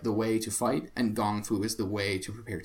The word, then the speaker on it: gong fu: which is funny because we spell it G O N G F U, gong fu tea, uh, which is funny because it's the same exact as um, it's the same root as kung fu.